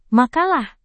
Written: makalah

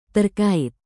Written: terkait

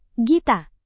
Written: gita

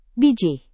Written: bijih

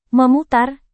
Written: memutar

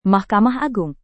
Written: mahkamah agung

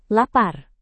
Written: lapar

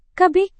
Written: kb

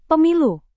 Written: pemilu

